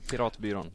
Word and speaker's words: Piratbyrån: So apart from the Pirate Bay system, there's also a group called Piratbyrån. Did I pronounce this correctly? – Quite nice. Piratbyrån. Yep.